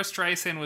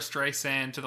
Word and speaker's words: Streisand: So Barbra Streisand was interviewed recently, and I guess Siri says her name wrong. It says it with like a hard, like with a Z instead of a soft S.